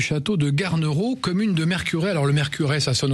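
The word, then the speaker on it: Mercurey: Mercuray.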